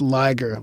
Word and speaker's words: Liger: When you previously read my camping story, it's Liger, like tiger, as in the words of Napoleon Dynamite.